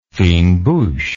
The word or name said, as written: fine bouche